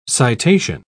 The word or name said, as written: citation